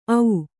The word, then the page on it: ಓ: ō